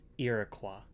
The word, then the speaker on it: Iroquois: It took me a while to even pronounce it correctly. – Okay, wait. Say it. Say it again. – Iroquois. It's okay. French.